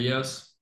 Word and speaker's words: Kyrgios: But for Alcaraz over here, in terms of the other tennis players I know, I literally think like the Greek guy, Nick Kyrgios, how do you pronounce it? – Kyrgios.